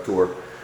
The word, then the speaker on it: Tours: I know it's spelled Tours, but the French don't, they don't pronounce the S, so we'll call him Martin of Tours.